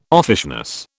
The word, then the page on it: offishness: British English